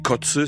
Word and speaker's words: Koze: connu sous le nom de DJ Kotze, ça s'écrit K-O-Z-E.